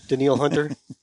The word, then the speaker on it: Danielle: Daniel Hunter. That's how you pronounce his name. – Is Daniel. – It? – It's spelled exactly like Danielle, but he pronounces it Daniel.